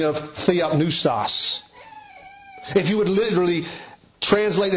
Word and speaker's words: theopneustos: The Greek word for inspired is a word pronounced theopneustos.